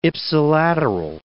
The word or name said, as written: ipsilateral